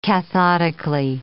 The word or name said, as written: cathodically